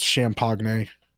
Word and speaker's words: Champaign: it's pronounced Champagne.